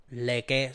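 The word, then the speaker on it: Lecque: Y por Chris Paul y Abdel Nadel se fueron Kelly Ubre, Ricky Rubio, Ty Jerome y Jalen Lecky. Leque, se escribe Leque. Leque.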